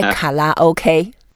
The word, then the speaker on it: karaoke: If you say karaoke, people may not respond instantly. You say karaoke. – Karaoke. – Yeah, ka. The sound sounds like English word, card.